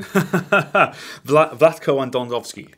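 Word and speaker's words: Vlatko Andonovski: Vlatko Andonovsky.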